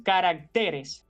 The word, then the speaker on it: caracteres: A propósito, insistimos en que el plural de carácter es caracteres y no caracteres, como a veces también escuchamos y vemos por ahí.